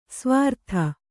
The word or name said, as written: ಸ್ವರಿತ